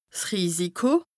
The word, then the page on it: 's Risiko: Bas Rhin; Strasbourg